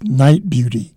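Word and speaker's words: Nite Bjuti: We began two selections from a trio called, I said dark, it's Night Beauty. And night is spelled N-I-T-E. Beauty is spelled B, as in black, J-U-T-I.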